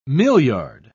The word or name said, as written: milliard